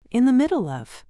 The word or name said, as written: in the middle of